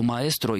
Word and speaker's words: маэстро: По поводу маэстро, я бы на всякий случай хотел подчеркнуть, что высокий стиль произношения предполагает все-таки «о» на конце, так же, как и барокко, и рококо. В этих случаях лучше не редуцировать «о», тем более, что маэстро и маэстра в испанском языке — это два разных человека.